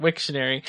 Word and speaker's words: Wiktionary: Wiktionary? That's how you say it. Wiktionary.